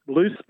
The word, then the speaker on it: Luce: It's Loose, but it's spelled L-U-C-E.